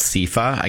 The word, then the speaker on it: CEFA: So with that in mind, where can we learn more about CIFA? Again, for our listeners, it's spelled CEFA.